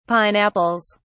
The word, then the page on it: pineapples: British English